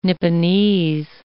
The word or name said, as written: Nipponese